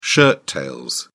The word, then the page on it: shirt tail: British English